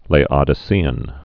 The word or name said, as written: Laodicean